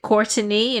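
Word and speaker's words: Courteney: That's how she pronounced it, Courtney, and there is an extra E in her name, Ross.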